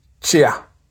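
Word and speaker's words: kyia: The next word is chia. Chia. And the sound focus is KY or ke, ye. Ke, yeah.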